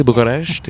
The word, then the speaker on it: Bucharest: We were filming in Bucharest, Romania. Am I saying that correctly? Bucharest? Yes. – You can say Bucharest. It's fine.